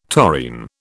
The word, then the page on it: taurine: British English